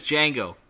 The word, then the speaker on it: Django: The dog's name is Django. Not with a silent D, like with a J.